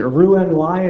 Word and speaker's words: Roux-en-Y: they got to testify that this didn't look like, is it Roux-en-Y, is that how you say it? – That's exactly right.